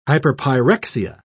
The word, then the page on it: hyperpyrexia: British English